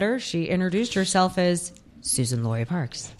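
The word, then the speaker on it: Suzan: Do we pronounce her Susan Laurie Parks or Suzanne Laurie Parks? – Susan. – I always heard it Susan too, but a podcast I listen to quite religiously always calls her Suzanne, and I yell at the podcast.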